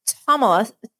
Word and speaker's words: Ptolemais: It says, "When we completed our voyage from Tyre, we reached Ptolemais." You don't pronounce P.